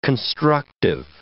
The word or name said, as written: constructive